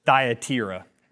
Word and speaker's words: Thyatira: So we've hit three churches now, and then the next church that we hit is this Thyatira is the correct pronunciation there. That's a tough pronunciation.